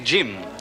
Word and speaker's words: Jim: Il faut prononcer Jim à l'anglaise avec un D devant.